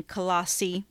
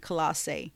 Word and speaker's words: Colossae: Now, Philemon lived in Colossae, or depending, you may want to pronounce it Colossae.